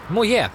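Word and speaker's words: Moyet: Is this love med Alison Hjälp mig, jag kan aldrig uttala det. – Moye. – Så är det.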